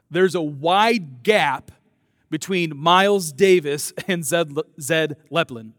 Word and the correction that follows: Led Zeppelin: Can I say it right? Led Zeppelin.